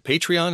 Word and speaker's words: Patreon: But we're set up on, is it Patreon? Is that how we pronounce it? – That's right. Yep.